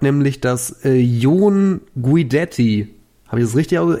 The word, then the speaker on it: John Guidetti: Ähm, laut Transfermarkt Aussprache, Moment. Jon Guidetti. John Guidetti. Genau der,